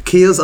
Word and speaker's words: kea: Key spelled K-E-A. – Kia. – Kia. – It's a Kia.